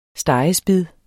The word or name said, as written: stegespid